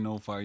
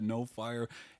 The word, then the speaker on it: Nofire: Zebediah No Fire. You can find me on Facebook. It's spelled as is Zebediah No Fire, N O F I R E, just as it sounds, baby.